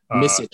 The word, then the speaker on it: Micić: Is it Micić? – Micić. – Micić. – Very Micić. Cool.